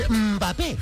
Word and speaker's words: Mbappé: Mbappé.